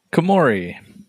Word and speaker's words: Komori: So, but then Virizion shows up to try to defend the forest along with the Komore clan. Is that how you pronounce that? – Komori. – Okay. The Komori clan to try to stop.